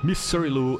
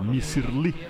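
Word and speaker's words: Misirlou: Missirlu é como os gregos pronunciam a palavra turca Missirli, que significa garota egípcia.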